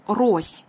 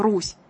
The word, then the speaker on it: Рось: И вот в VII-VIII веках восточнославянские племена, которые жили по берегам Днепра, постепенно объединялись под началом племени Рось, или другое его произношение «Русь», чередуются звуки «о», «у».